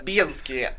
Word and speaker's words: Bizkit: Bizkit or biscuit. Do you pronounce the Z? – Bizkit.